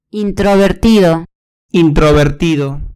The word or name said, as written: introvertido